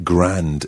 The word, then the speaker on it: grand: It looks like grand in English. G-R-A-N-D, but pronounced grand.